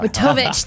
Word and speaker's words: Wojtowicz: Watovich. Thank you. Thank you, Zach.watovich at Buildertrend for helping me say your last name.